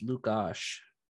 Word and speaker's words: Lukas Dostal: I've been told it's Lukas, like you pronounce it Lukas, but Lukas. – You add Dostal at the end of his name. – That is how I was told to pronounce it. By one of my friends I know from the ice crew, because she is from the Czech Republic.